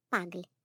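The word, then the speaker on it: puggle: Порода Маффи — пагль. Родители Маффи — мопс и бигль. По-английски — мопс — паг. Произносится как пагль от бигля.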